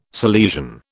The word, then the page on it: Salesian: British English